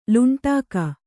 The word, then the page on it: ಲುಂಟಾಕ: luṇṭāka